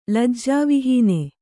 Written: ಲಜ್ಜಾವಿಹೀನೆ

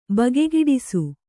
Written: ಬಗೆಗೆಡಿಸು

